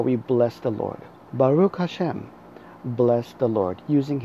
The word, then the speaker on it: Baruch: The second word I'd like you to learn is Baruch. B-A-R-U-C-H. It's pronounced Ba-Ruch. Notice the Ch at the end. It is a guttural sound.